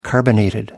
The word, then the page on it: carbonated: American English